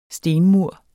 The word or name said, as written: stenmur